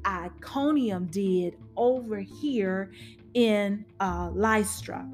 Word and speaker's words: Lystra: Here is the second opposition that they have encountered in Lystra. Is that how we're going to pronounce that? Yes, Lystra.